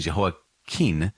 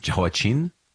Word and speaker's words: Jehoiakim: Now, there was Jehoiakim with an M at the end, and Jehoiakim with an N at the end. I've also heard his name pronounced Jehoiachin with a kind of soft ch. Jehoiachin, but Jehoiakim is how I committed to saying it when we were reading 2 Kings and 2 Chronicles.